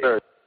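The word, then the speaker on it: Serge: I believe it's Surge, like the former beverage that WCW advertised often.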